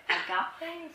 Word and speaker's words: agape: And they equate it to the Christian word in Greek philosophy of agape, is how you pronounce that, which means charity, the highest love, especially between man and God.